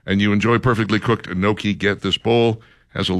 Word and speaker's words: gnocchi: Creamy pesto gnocchi bowl. – Gnocchi. – G-N-O-C-C-H-I.